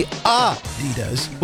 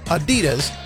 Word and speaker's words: Adidas: It's supposed to be Adidas with a slight emphasis on the first syllable, not Adidas, stressing the second.